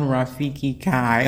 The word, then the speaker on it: Rafiki Cai: So there's only one Rafiki Kai on LinkedIn. That's spelled R-A-F as in first, I-K-I, surname C-A-I.